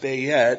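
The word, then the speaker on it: Beit (בית): It's, I think in Hebrew it's better pronounced Beit as in house.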